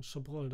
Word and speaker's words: Chabrol: Claude Cabrol. Oder Chabrol, wie wird der nochmal ausgesprochen? Chabrol dann, ne?